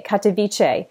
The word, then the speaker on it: Katowice: Katowice.